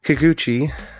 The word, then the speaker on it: Kikuchi: So it's like the American Vampire Hunter D, but without all of the wonderful humor that Hideo Kikudu... I can't say my Japanese today. Kikuchi brought to the work.